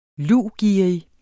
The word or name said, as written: luvgerrig